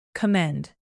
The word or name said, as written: commend